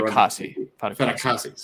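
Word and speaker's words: Fatukasi: I mean, Fedakusi, I believe is yeah. how it's pronounced. – Yeah, uh, is one of the better Fedakasi. See, I knew I was wrong, but I was like, I don't know why.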